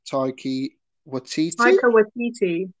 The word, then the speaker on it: Taika Waititi: Obviously, the director is Taika Waititi.